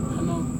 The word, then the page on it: hanong: Serewen